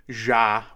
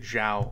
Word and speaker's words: Zhao: We've buried the lead because Nomadland from Chloe Zhao, which is the correct pronunciation, I am told, and it's a terrible job by me for pronouncing it Zhao all this time.